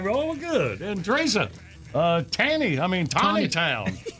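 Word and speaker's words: Taneytown: Tanny Town, Maryland? Is that you? Where you are, Teresa? – You know, Jackie is sitting right behind you. You could just ask. – Yeah. It's pronounced Tawny Town. Tawny Town.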